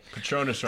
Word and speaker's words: Patronus: Patronus rhymes with anus.